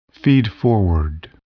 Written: feedforward

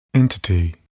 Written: entity

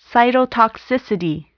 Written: cytotoxicity

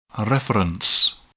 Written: reference

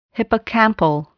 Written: hippocampal

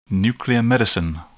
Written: nuclear medicine

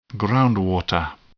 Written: groundwater

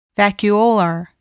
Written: vacuolar

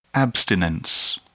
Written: abstinence

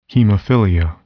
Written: hemophilia

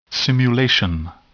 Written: simulation

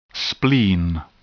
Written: spleen

